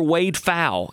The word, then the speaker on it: Pfau: His name is Dr. Wade Pfau, and it's it's spelt with a P, P F A U, believe it or not.